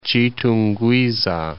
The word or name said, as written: Chitungwiza